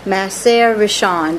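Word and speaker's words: Ma'aser Rishon: Of course, you have the Maser Rishon. That's how you pronounce that. Maser Rishon.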